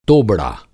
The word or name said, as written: तोबड़ा